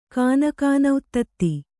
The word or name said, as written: ಕಾನಕಾನಉತ್ತತ್ತಿ